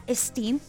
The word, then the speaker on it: Sting: Así es, fue escrita por este gran vocalista Sting.